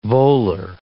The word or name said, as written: volar